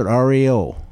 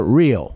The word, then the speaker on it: REO: Went on his own again, and he couldn't use the name Olds, so he just used his initials, and we pronounce it REO. – REO. – That's what the band and them pronounce it now as REO. We call it Rio.